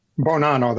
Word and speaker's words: Bonanno: It looks like Bonanno, but how do you say it? – Bonanno, that's it.